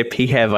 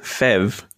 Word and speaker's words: PHEV: You say PHEV, I say FEV. So it's very interesting hearing the different ways it's pronounced.